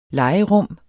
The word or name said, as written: legerum